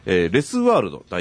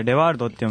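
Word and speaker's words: Les World: レスワールド代表の指導、それレワールドって読むんですよ。そうなんですよね、これね、フランス語なんですけどね、レが。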